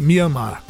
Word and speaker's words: Mianmar: Myanmar. Né? Alguns falam Myanmar, Myanmar, né? mas ou Myanmar, porque é o M-I-A-N-M-A-R, Myanmar, né? E às vezes pode ser uma pronúncia assim, Myanmar.